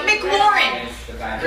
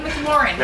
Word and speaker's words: McLaurin: Terry McLaurin. – Really? – Is that the right way to say it? – Yeah, that sounds right. – Terry McLaurin.